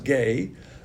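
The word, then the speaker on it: gay (γῆ): That word is gay, which is spelled G-E, pronounced gay.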